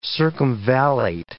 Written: circumvallate

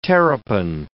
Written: terrapin

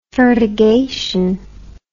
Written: fertigation

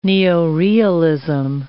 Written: neorealism

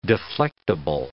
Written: deflectable

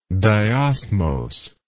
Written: diosmose

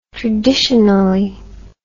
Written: traditionally

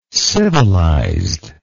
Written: civilized

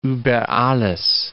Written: über alles

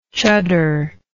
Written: chuddar

